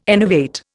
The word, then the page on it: enervate: Indian